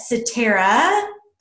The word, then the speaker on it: Satara: but Satara, if you would like to um, go ahead, is the, did I pronounce that right?